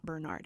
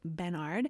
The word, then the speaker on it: Benard: Bernard is spelled B-E-N-A-R-D. It's not Bernard, it's Bernard.